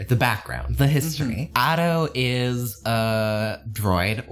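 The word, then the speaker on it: Ato: It's actually pronounced Otto. I've been pronouncing my character's name wrong. It's not Otto. It's A-T-O, not A-U-T-O.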